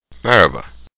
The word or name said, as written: merhaba